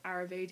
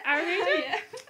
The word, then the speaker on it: Ayurveda: Last week, the lovely Barry covered the topic of Ayurveda. Did I say that right? – Yeah. – Ayurveda? – Yeah.